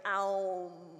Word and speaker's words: Aum: Om. Or I was taught to pray it, to say it correctly, Aum. Aum.